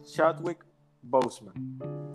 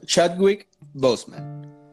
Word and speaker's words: Chadwick Boseman: no sé si se pronuncia así, pero Chadwick Boseman, ¿será? – Sí, Chadwick, yo le diría Chadwick Boseman. – Exacto, Chadwick Boseman, nuestro Black Panther, que bueno, que en paz descanse.